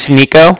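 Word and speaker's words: Niko: Oh, my name's Nico. – Mm-hmm. – That's N-I-K-O.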